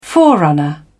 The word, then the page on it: forerunner: British English